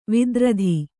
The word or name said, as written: ವೈಧೃತಿ